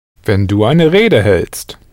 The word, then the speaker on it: hältst: How would you pronounce the du form, spelt H-A umlaut L-T-S-T? – Du hältst. – Du hältst.